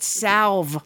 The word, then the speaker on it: salve: Is salve, that you, it has an L in it. – Yes, that you used the L in salve. – He's been leaving out those silent H's left and right all day. – That's because they're silent, but salve.